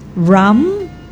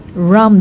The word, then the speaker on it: Hram: Si Ram, H-R-A-M, Ram de la Torre, silent H.